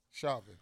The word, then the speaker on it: Chauvin: Yesterday was a historical moment for black people in the judicial system here in America because of the George Floyd, Derek Chauvin case. Wait, how you say his name? – Chauvin.